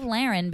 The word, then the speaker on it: Laren: By the way, I love Laren because it rhymes with Darren.